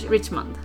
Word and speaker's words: Richmond: Uttalade jag ditt efternamn rätt nu? – Ja, Richmond. – Yes, ja. Det stämmer bra.